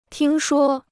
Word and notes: 听说: tīng shuō

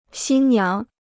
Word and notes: 新娘: xīn niáng

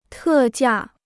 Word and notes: 特价: tè jià

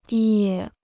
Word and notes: 野: yě

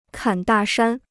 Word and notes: 侃大山: kǎn dà shān

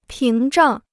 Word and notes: 屏障: píng zhàng

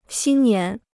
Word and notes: 新年: xīn nián

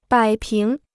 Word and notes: 摆平: bǎi píng

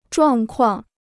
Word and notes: 状况: zhuàng kuàng

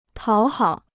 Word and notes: 讨好: tǎo hǎo

